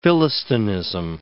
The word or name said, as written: philistinism